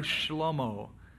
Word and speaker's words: Shlomo: There let Zadok the priest, Nathan the prophet, anoint him king over Israel and blow the horn and say, long live King Shlomo. That's his Hebrew name. Solomon is an English mispronunciation. It's actually Shlomo.